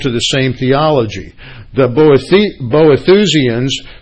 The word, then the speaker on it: Boethusians: Boethusians. There's a new word for you this morning. That's spelled B-O-E-T-H-U-S-I-A-N-S, Boethusians.